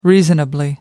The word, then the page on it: reasonably: American English